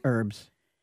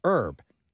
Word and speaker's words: herb: Herbs. – So Cecilia says, do you pronounce the H in herb? I do. And my husband always tells me I'm wrong and that it's a silent H.